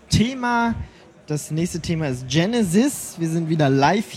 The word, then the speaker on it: GeneSEZ: Und dazu ist jetzt bei mir Gerrit Beine von Genesis und ähm, vielleicht sagst du mal, man sagt zwar Genesis, aber irgendwie wird es ja ganz anders geschrieben. Vielleicht kannst du mal kurz sagen, warum das so heißt und wie man es dann überhaupt schreibt. Genesis schreibt man Genesetz.